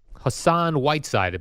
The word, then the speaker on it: Hassan Whiteside: Hassan Whiteside, am I saying his name correctly? – Yes, you are, from the Heat.